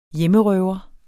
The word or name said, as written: hjemmerøver